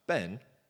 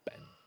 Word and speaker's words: Ben: It means sun in Hebrew, mountain in Scotland, rhymes with hen and pen. But that's the end of it. It's just Ben. When you add Higgs to the end, it becomes me, Ben Higgs.